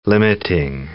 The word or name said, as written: limiting